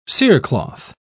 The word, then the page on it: cerecloth: British English